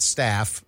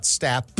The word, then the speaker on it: Stapp: It is not Scott Staff. It is Scott Stapp.